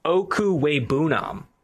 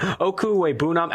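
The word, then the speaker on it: Okwuegbunam: Number five on the big board at the tight end position, Albert O, that's what they call him on campus, but I'm going to go ahead and pronounce his name here. Okuwebunam. I looked up the pronunciation right before here, but Okuwebunam, Albert Okuwebunam, number five tight end on the board.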